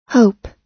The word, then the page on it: hope: American English